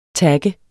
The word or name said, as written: TAGGEDE